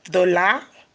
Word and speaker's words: de la: The examples of partitif article are do, dollar, dull, day. Do, dollar, dull, day. Now, do is spelled D-U. Dollar, D-E-L-A. Dull, D-E-L apostrophe. And day, which is spelled D-E-S.